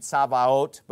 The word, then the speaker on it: Sabaoth: And we find the term Lord of Sabaoth in Romans 9 and James chapter 5, which is quoting the Old Testament hosts, or Hebrew pronunciation, Sabaoth, but we usually say Sabaoth in English, right? And it's not the Sabbath, all right? It's got an O in it.